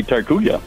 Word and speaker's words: Tortuga: It's gotta be Tortuga. – That's not how you say it. Tortuga.